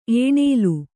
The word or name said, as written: ಏಣೇಲು